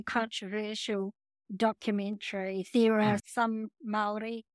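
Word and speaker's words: Māori: The Maori, yeah.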